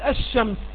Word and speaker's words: الشمس: ويوجد حروف شمسية لما نقول الشمس، هل تلفظنا باللام؟ لم نتلفظ بها.